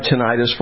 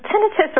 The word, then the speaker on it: tinnitus: Well, can you describe tinnitus for us, please? – Yeah, so tinnitus or tinnitus, both are pronounced correct, is what most people describe as ringing in their ears.